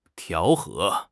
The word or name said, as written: 调和